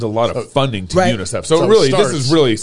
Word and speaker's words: UNICEF: It's going to come here first to UNICEF? – UNICEF. – Is that how you say it? – That's how we say it.